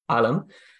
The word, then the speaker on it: Ilam: How do you pronounce that? – Ilam.